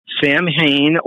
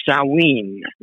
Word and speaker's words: Samhain: It's called Samhain or Samhain. That's the way it's actually pronounced.